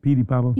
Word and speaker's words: Petey Pablo: I said that right, Petey Pablo? – Yeah, I know.